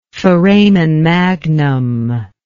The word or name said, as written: foramen magnum